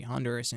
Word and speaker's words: Honduras: Juan is a student here at NC State University studying business administration, and he is from Honduras, or Honduras. That's how it's pronounced in Spanish?